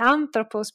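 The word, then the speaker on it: anthropos (ἄνθρωπος): So I made the example of atoroko, that basically must have been pronounced something like anthropos, because of course is a Greek dialect.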